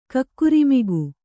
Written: ಕಕ್ಕುರಿಮಿಗು

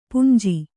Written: ಪುಂಜಿ

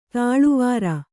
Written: ತಾಳುವಾರ

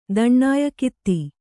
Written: ದಣ್ಣಾಯಕಿತ್ತಿ

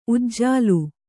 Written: ಉಜ್ಜಳ

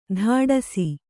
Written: ಧಾಡಸೀ

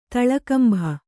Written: ತಳಗಂಬ

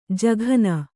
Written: ಝಗನೆ

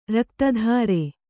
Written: ರಕ್ತಧಾರೆ

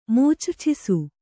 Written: ಮೂಚ್ರ್ಛಿಸು

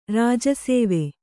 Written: ರಾಜಸ್ವ

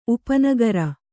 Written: ಉಪ್ಪಿನಾಗರ

